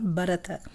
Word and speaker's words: Bharata: Il y a trois syllabes Bharata. Bharata, mais on les prononce ensemble, Bharata.